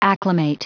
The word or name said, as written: acclimate